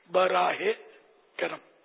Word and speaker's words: براہ کرم: ہمارے یہاں وہ لکھتے براہ یا کر کے حمزہ لگاتے ہیں, یہ غلط تلفظ ہے, بلکہ صحیح تلفظ ہے بے رے الف ہا ہے, اس کے نیچے زیر مدینہ کے آخر میں جو ہے آتی ہے نا وہ اس کے نیچے زیر ہوگا براہ کرم.